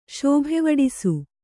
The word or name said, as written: ಶೋಭೆವಡಿಸು